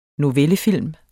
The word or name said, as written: novellefilm